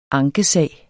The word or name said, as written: ankesag